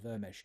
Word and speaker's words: Vermes: It's pronounced Vermesh. It's written Vermes. I used to think it was Vermes, but it's actually pronounced Vermesh.